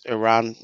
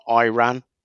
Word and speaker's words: Iran: Because first of all, you pronounce my country's wrong. It's Iran, not Iran.